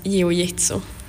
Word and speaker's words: jiu-jitsu: Och du skulle hjälpa mig, hur säger man det? – Brasiliansk jiu-jitsu. – Ja, precis.